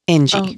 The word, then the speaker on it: Inji: So it's spelled I-N-J-I. Ingie. – Okay, Ingie. Okay.